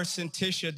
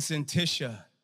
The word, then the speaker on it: Syntyche: And believe it or not, I didn't know how to pronounce that word, and I looked it up, and there must have been like 5 or 6 different pronunciations of Syntyche, and I liked that one.